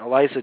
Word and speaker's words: Eliza: Alicia, I always mispronounce her name, Eliza Dushku.